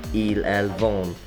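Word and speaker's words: vendent: Third person plural, il-elle vend, silent E-N-T is added, but the D is more pronounced. – Nous vendons with O-N-S added, and vous vendez with E-Z added.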